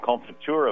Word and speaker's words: confiture: Yeah, it's a French word, confiture. It's spelled C-O-N-F-I-T-U-R-E, I believe.